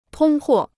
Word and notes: 通货: tōng huò

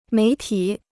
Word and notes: 媒体: méi tǐ

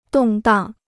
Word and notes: 动荡: dòng dàng

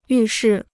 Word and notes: 预示: yù shì

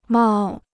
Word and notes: 冒: mào

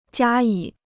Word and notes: 加以: jiā yǐ

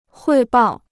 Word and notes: 汇报: huì bào

